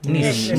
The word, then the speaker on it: Nice: Bacanya gimana sih? – Nice, Nice ya, Nice ya, ya di kota Nice gitu.